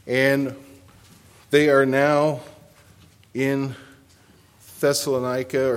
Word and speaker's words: Thessaloniki: It's spelled Thessaloniki, I believe. It ends with an I instead of an A, and it's a K-I instead of C-A, like the scripture.